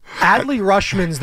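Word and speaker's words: Adley Rutschman: Audie Rushman, who we're going to see in the home run derby. – Adley Rushman's the pronunciation.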